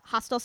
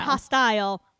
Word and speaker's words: hostel: She was initially assigned a like hostel style room. Did I say that right? Yes. – Yeah. – Yeah. – Hostel. – Hostel. – Not hostile. Hostel. I always get that wrong.